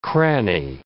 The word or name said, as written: cranny